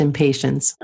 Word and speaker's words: Impatiens: And one that's really helpful is called Impatiens, which is really easy to remember what it's for, because to me it sounds a bit like impatience. – And here in the States, it is pronounced impatience.